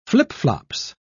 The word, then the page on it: flip-flops: British English